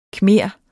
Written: khmer

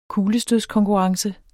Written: kuglestødskonkurrence

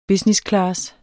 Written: businessclass

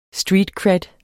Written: streetcred

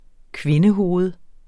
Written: kvindehoved

